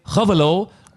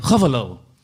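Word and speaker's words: Gouweleeuw: Jeffrey Hovelow, und den darfst du jetzt buchstabieren. Schaffst du das? – Oh, das ist gemein. – Also den Nachnamen. – Ja. – Jeffrey Hovelow. Wie schreibt man Jeffrey Hovelow? – G-O-U.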